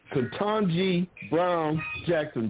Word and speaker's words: Ketanji Brown Jackson: Ketanji Brown Jackson.